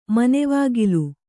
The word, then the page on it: ಮನೆವಾಗಿಲು: Kannada